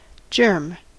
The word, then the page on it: germ: American English